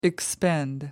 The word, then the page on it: expend: American English